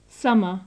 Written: summer

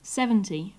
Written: seventy

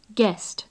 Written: guest

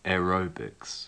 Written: aerobics

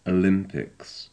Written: olympics

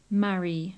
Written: marry

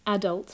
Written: adult

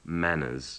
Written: manners

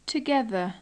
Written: together

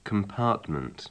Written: compartment

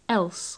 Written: else

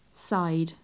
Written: side

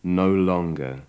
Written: no longer

